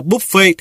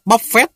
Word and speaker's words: buffet: Lên chiến lược bài bản, buổi trưa đã phải nhịn đói, đi ăn buffet, đọc là buffet theo kiểu tiếng Pháp, chứ không phải là buffet theo tiếng Anh đâu nha.